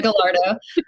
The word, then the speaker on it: Gallardo: That's the proper way to say it, but I can't even say it like that. – So I just Gallardo. Like, Yeah, Gallardo.